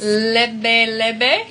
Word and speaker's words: lebe lebe: Lebe lebe? Yes. Is that how you say it? – Yeah, lebe lebe.